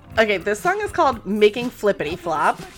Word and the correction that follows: Making Flippy Floppy: Making Flippy Floppy. Come on, let's get it. – Oh, Flippy Floppy. Sorry, I'm just adding more syllables to it.